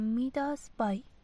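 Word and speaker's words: MidasBuy: معذرت می خواهم، من فکر کنم تلفظ سایت رو اشتباه گفتم. میداس بای.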